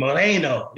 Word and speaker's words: Moreno: That's like one of those pronunciation things that you gotta roll the tongue, though, really, if you really want to pronounce it right in Spanish, you know what I mean? Moreno, you know what I mean? – Oh.